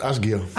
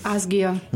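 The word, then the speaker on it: Ásgeir: MDR Kultur mit Musik von Ausgeier. Wir spielen wechselnd, ich glaube, so spricht man den. – Ich glaube, der heißt Asgeir. – Asgeir. – Ist Isländer.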